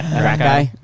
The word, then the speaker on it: Gracchi: I don't know what the Grokey brothers are. – Gracchi. – Gracchi.